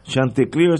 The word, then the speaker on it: Chanticleer: Chanticleer se escribe C-H-A-N-T-I-C-L-E-E-R. Las últimas palabras son como leer. Chanticleer, C-H-A-N-T-I-C-L-E-E-R.